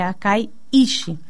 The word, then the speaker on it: Akaiishi: E o dojo Akaiishi, né, que é difícil de pronunciar, mas é Akaiishi, Ele estará está eh, atendendo agora em novo local, que é do lado da rodoviária, em cima da Agromitos.